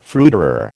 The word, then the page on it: fruiterer: American English